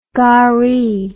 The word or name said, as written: garri